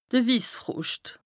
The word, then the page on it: de Wissfroscht: Bas-Rhin; Strasbourg